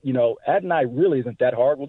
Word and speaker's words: Adonai: It's Adonai. So just Adonai. Adonai Mitchell. – So it is three syllables, though. It's not two, Adonai. It's Adonai.